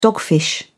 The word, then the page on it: dogfish: British English